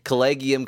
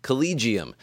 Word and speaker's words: collegium: Collegium can also be pronounced collegium but potato, potato, collegium, collegium, I'll say collegium.